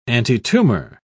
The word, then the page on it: antitumor: British English